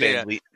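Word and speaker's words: VanVleet: I think Fred Van Fleet pronounced that name for me. How do you pronounce it? – It's just Van Vliet.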